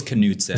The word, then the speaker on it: Knutson: When it's in that name, it's Knutson. Knutson. At least the K-N... The Knutsons I've known.